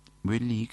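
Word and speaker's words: Boëly: Boilly, que se escribe con O, E, con diéresis, L, Y, Boilly, fue un compositor que nació en 1785 y muere en París en 1858.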